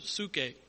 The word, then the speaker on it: psuche: In the New Testament, the word translated soul comes from the Greek word psuche, P-S-U-C-H-E. Seen it spelled different ways, but basically the spelling in English only helps you so you can pronounce it. So we see nephesh in the Hebrew. We see psuche, P-S-U-C-H-E, in the Greek for this word soul.